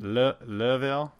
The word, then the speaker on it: Louisville: and that's in Louisville, Kentucky. Hopefully I said that correctly. – Louisville.